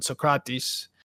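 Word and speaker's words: Sócrates: So I dabbled into it, and I was able to make about 45,000 coins on Socrates.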